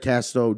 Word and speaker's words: Castro: Ladies at home, if you don't have an extra pair of panties, you're going to fucking need them because we got Garrett Castro, J-Lad, also Justin Ladner.